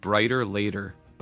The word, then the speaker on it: Bryter Layter: Number 9 on my list of my favorite albums of 1970 is an album called Brighter Later by Nick Drake. That's spelled B-R-Y-T-E-R-L-A-Y-T-E-R.